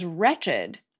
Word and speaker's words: wretch: Another example of ED turning an adjective into a longer adjective is wretched. It comes from an Old English word that's pronounced basically the same way as the noun wretch is today, except that the W wasn't silent in Old English.